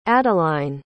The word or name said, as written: adeline